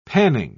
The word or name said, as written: panning